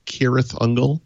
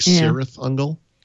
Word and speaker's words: Cirith Ungol: like Cirith Ungol. Mm-hmm. And then you find out years later that you pronounced it wrong and it's in your head.